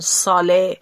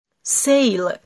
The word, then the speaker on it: sale: آن سیل باشه یعنی یک وسیله یا یک چیزی در حراج قرار گرفته، که کلمه سیل رو خیلی جاها میشنویم اشتباه تلفظ میکنن، مثل مثلا ساله یا سیلی. یادتون باشه S-A-L-E رو هر جا دیدین سیل تلفظ میکنیم.